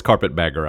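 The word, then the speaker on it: carpetbagger: Go back up north, you carpet beggar. – Okay. – I know it's carpet bagger. I was mispronouncing it.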